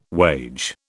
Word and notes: wage: British English

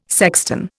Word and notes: sexton: Indian

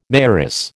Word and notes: mayoress: American English

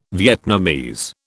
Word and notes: Vietnamese: British English

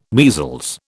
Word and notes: measles: British English

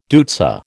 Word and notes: deutzia: American English

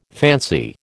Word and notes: fancy: American English

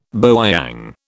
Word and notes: bowyang: British English

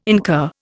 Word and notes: incur: Indian

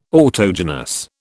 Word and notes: autogenous: British English

